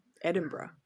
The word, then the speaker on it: Edinburgh: What's the correct? – Edinburgh. – Edinburgh. To like bruh at the end. That's basically how they say it.